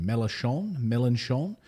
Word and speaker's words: Mélenchon: Mélenchon, is openly calling for leaving NATO, stating that the warmongering U.S.-led military cartel is useless and creates tensions everywhere.